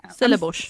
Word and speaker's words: Stellenbosch: Stellenbosch. – Stellenbosch.